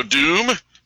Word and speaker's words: Doom: Hello, Doom. It's pronounced Doom.